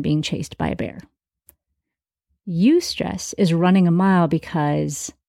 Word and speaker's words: eustress: There's stress as we know it, and then there's also eustress. It's spelled E-U-S-T-R-E-S-S, eustress.